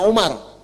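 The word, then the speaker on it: Umar (عمر): Nah, kalau dibaca begini, Amr namanya. Kalau wawunya hilang, bacanya Umar.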